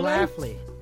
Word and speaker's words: Schlafly: Schlafly.